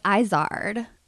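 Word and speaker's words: Izard: Well, it's Izzard. – Oh, I've been pronouncing it wrong.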